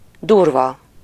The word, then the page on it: brusque: /bʁysk/; France